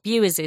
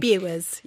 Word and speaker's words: Bures: And that's something, if you've come across it, you might not know it's called the White Dragon of Bewers, because Bewers is spelt B-U-R-E-S. Who would imagine to call it Bewers from such a spelling? But that's what Rosie informs me is the correct spelling. – It's Bewers, yes.